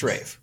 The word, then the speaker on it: Ralph: That's how I've always heard it. I've always heard it pronounced Rafe. Always. – No. – I don't mind calling him Ralph because that's how it's spelled.